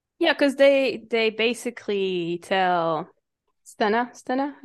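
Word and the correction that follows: Stheno: am I saying it right? – Steno.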